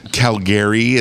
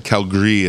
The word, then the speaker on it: Calgary: Calgary is not how you say it, but Calgary is how you say it.